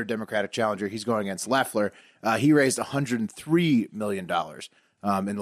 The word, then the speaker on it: Loeffler: Both seats are up for grabs, the regular seat that's currently held by David Perdue and the special seat held by Kelly Loeffler, spelled Loeffler, pronounced Loeffler.